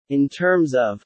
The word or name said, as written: in terms of